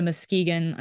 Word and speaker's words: Muskegon: There's um, there's a line here, and I'm finding from my father drives to Muskegon.